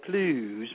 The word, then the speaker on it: Clowes: That's September the 10th at St. Louis and September 11th at the Clues Memorial Hall. That's how it's pronounced, believe it or not, Clues. It's spelled C-L-O-W-E-S.